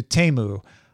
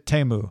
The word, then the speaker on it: Temu: Apparently, Temu has changed the pronunciation of Temu to Temu, because I looked at last year's Temu Super Bowl ad and they pronounced it Temu.